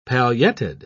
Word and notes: pailletted: British English

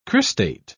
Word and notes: cristate: British English

